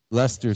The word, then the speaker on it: Leicester: There were thousands of fans in Leicester Square. Did I say that right? – Leicester Square.